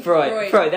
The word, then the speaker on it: Freud: Sigmund Freud.